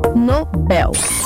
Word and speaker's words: Nobel: contrário, você vai ver que não tem acento. Se não tem acento, a pronúncia correta é Nobel.